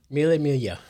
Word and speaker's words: Mille Miglia: The yeah, you can pronounce yeah. that. – Mille. – Mille Miglia. – The Mille Miglia. – Which is just 1,000 miles.